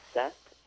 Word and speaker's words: Seth: My name is Seth. That's spelled S-E-T-H.